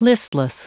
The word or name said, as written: listless